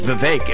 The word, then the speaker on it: Vivek: I say my name's Vivek. It rhymes with cake.